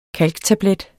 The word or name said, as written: kalktablet